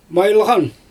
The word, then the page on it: majylGan: Xyzyl